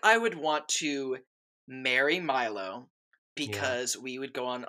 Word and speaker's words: Milo: Is it Milo or Milo? – It's Milo James Thatch. That's like how they say it in the movie.